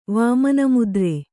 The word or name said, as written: ವಾಮನಮುದ್ರೆ